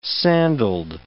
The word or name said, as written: sandaled